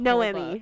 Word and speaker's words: Noemi: I felt like Naomi was so Naomi. I say Naomi. – Oh God, we're gonna pronounce her name so wrong. This whole Noemi.